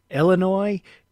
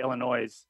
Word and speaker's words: Illinois: Some people say Illinois with an S or a Z at the end.